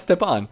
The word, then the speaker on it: Stepan: Why can I still not pronounce Stepan? I can't do it. – It's like, you know... You step on a bug.